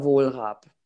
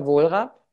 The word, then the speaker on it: Wohlrab: Oh, you can find me under Elements of Birth or Jutta Wohlrab. both things. Instagram is Jutta Wohlrab.